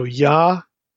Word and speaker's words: Yahweh: So Yahweh instead of Yehweh or Yeh.